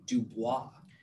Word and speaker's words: Dubois: Dubois.